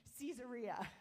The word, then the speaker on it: Caesarea: Sometimes I hear it Caesarea. I don't want to get hung up on this. But I did look up the pronunciation, and it says Caesarea.